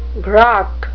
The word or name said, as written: grok